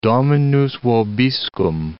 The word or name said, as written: Dominus vobiscum